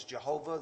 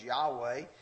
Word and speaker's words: Yahweh: It's pronounced, as we say it, if you're the older way was Jehovah, the newer way is Yahweh.